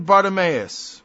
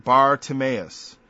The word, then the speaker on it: Bartimaeus: Blind Bartimaeus. I think I pronounced it wrong. Bartimaeus, the son of Timaeus.